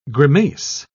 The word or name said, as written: grimace